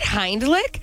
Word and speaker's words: Heimlich: Wait, am I saying it wrong? It's not Heimlicher.